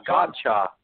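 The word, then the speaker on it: Godchaux: I'm gonna say the X is silent. I haven't gotten the phonetical spelling. – No, no, you actually did it right the first time, MC Money. Gotchaux. Very good.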